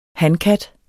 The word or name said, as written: hankat